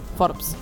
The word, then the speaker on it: Forbes: Forbes.